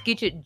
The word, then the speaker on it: Gidgit: So we've done just over 200 podcasts now, but everyone spells my name wrong, so it's Gidget, G-I-D-G-I-T, not E-T, because I'm not contemporary.